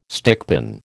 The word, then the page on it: stickpin: American English